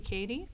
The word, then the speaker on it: Cady: Darcy Katie. – And can you spell that for me? – D-A-R-C-I-E. Last name C-A-D-Y.